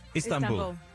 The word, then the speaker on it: Istanbul: Am I pronouncing that right, by the way? Istanbul? – Istanbul. – Istanbul. – Istanbul. – Istanbul. Istanbul. – Yeah. – You sure? – Not ease.